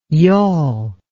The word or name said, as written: y'all